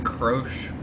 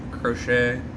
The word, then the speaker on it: Croce: Jim Croce. Crochet. I don't know. – Crotch? – Croce. – Okay, does, like, bad Leroy Brown.